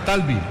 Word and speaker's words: Talbi: El número 7 es Talbi, con acento en la primera A. Talbi, número 7.